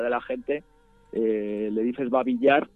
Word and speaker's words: Babi Yar: No sé si lo digo bien, es Babi Yar, es un barranco. ¿Qué ocurrió allí? – Exacto. Sí, esto es el famoso barranco de Babi Yar,